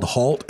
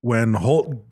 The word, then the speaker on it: Holt: So at this point that morning, apparently Lieutenant Colonel Holt, they kept saying Holt. – It's Holt. – But it's pronounced, it's spelled Halt, H-A-L-T. – H-A-L-T, Halt.